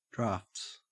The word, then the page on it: draughts: Australian English